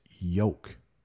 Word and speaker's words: yolk: and it's yolk. It's the same, it's spelled differently, but it's pronounced the exact same way as the thing that is holding those two oxen together.